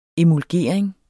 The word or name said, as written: EMULGERING